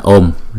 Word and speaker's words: Om: Thì chữ này chúng ta đọc là Om, hay không? Chữ O là hai nhịp. Thì chúng khi chúng ta đọc chúng ta kéo dài chữ O trước rồi mới đóng lại, đóng chữ O nó lại là Om, lại M.